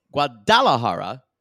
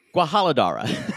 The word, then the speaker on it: Guadalajara: So we have a couple of Guadalajara pronunciations. Guadalajara from Guadalajara. – Mm-hmm. – Guadalajara. Then one of her, either her sister or her mother or her friend says Guajaladara.